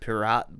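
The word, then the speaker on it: Piraat: The beer, I may mispronounce this, I believe it's pronounced pirat, but it's obviously a reference to pirates, judging by the pirate ship. It's P-I-R-A-A-T.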